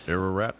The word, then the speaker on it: Ararat: Ararat, I think that's how it's pronounced. – Ararat.